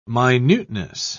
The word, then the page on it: minuteness: British English